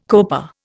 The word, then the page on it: coper: Indian